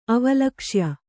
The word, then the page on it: ಅವಲಕ್ಷ್ಯ: avalakṣya